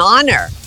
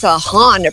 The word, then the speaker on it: honor: Você vai dizer it's an honor. Você não vai dizer it's a honor, porque não é honor, é honor.